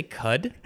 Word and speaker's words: Qud: It was kind of like, did you say CUD? – Yes.